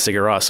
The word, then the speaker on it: Sigur Rós: Sigur Rós.